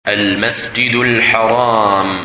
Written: Al-Masjid Al-Haram

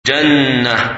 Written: Jannah

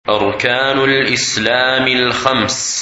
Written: Five Pillars of Islam

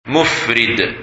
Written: Mufrid

